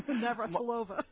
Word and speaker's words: Navratilova: Navratilova.